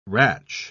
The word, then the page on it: ratch: British English